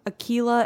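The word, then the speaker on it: Akeelah: My actress was in, I always say this wrong, Aquila and the Bee. – I think you got it.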